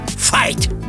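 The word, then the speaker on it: Veit: А в Германии вообще Файт. – Ого, Файт – это вообще другое имя. – Не-не-не-не, Весночка. Пишется оно просто через В. Файт. А читается Файт.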